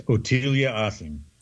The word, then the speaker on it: Ottilia Assing: Perhaps the most curious is his relationship with, and I'm going to let you say her name because I'm not sure how to pronounce it. – It's Ottilia Assing.